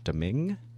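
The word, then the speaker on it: Domingue: And correct my pronunciation, but Deming? – Yes, Louis Deming.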